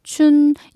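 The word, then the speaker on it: 춘 (春): 가다는 취, 봄은 춘. 이건 정말 봄 춘자의 발음 거의 그대로네요.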